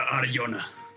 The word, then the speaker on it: Arjona: Creo que se pronuncia Arjona. – Arjona. Ah, Arjona. Arjona. Así es.